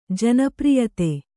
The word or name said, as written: ಜನಪ್ರಿಯತೆ